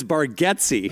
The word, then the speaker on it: Bargatze: So it's Bargatze.